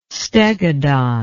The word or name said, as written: stegodon